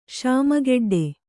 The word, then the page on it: ಶಾಮಗೆಡ್ಡೆ: śama geḍḍe